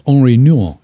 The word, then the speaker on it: Henri Nouwen: I'm gonna start quoting a lot of Bono and a lot of Henry Nouwen. So, you know, then you'll know. – I think it's Henri Nouwen.